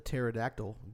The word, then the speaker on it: pterodactyl: Why can't you hear the pterodactyl go to the restroom? – P is silent.